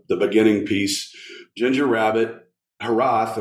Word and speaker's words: Hiraeth: That's kind of the fun with the name, but it's a Welsh term that's technically pronounced Hiraeth, but I normally say Hiraeth because that's how it phonetically looks.